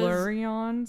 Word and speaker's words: Silmarillion: I always say it wrong. – I thought it was. Silmarillion, simil, whatever.